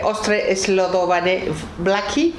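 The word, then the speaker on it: Ostře sledované vlaky: Ostre Slodovane Blacky. – Sí. – Estará mal pronunciado. – No, estaba pronunciado muy bien.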